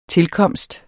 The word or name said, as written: tilkomst